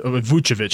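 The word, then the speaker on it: Vučević: We have Vukovic. He's headed to Vucevic. Sorry, I pronounced that incorrectly.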